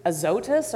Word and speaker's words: Azotus: But here he is, finishes baptizing this wonderful Ethiopian, and then he's just whisked away to Azotus, or how you pronounced it beautifully, thank you.